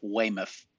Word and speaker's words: Weymouth: It's actually pronounced Weymouth.